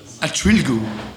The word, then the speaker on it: Entschuldigung: Entschuldigung. – Huh? Entschuldigung? – Yeah. – Entschuldigung.